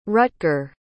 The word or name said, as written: rutger